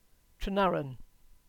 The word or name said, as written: Trenarren